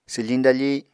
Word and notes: swfiñ dafi: apatani

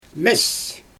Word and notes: mes: Xyzyl

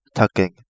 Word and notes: Takenge: Kapingamarangi

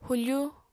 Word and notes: hufu: apatani

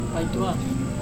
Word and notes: paitua: Serewen

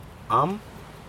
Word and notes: aM: Aneityum

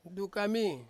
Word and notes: dukami: Ansus

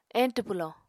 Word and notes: eñtw pwlò: Apatani

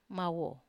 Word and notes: mawo: Wamesa

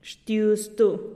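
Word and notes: xtiuztu: Teotitlán del Valle Zapotec

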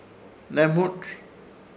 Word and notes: Hnemũrz: Ishir Chamacoco

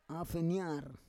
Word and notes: afenyar: Roon

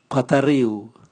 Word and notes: bwatariu: Raga

